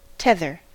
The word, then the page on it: tether: American English